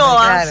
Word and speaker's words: Ainhoa: Así que se llama Ainhoa. – No sé. Se va a llamar Messi. – Ainhoa. – No jodas, no me pongas ese nombre. – Ainhoa, sí, porque la H no se estaría pronunciando.